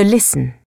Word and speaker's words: listen: Par exemple, certaines lettres ne se prononcent pas. Le W de wrong, le T de listen.